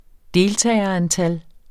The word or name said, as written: deltagerantal